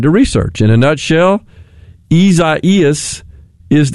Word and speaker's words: Isaias: Isaias. – Isaias. Gotcha. So an extra syllable in there, it would appear, from the way it's typically pronounced, spelled I-S-A-I-A-S.